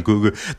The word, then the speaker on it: Coogler: Because when you look at Black Panther, directed by Ryan Coogler, Yeah, that's how you say his name, Ryan Coogler.